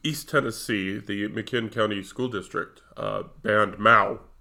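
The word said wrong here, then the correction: Maus: or how do you pronounce that? – I think Mouse? It's Mouse.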